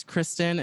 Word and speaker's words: Christin: My name on Instagram is Christin. It's spelled C-H-R-I-S-T-I-N, like Christ in, not Christian, Christ in.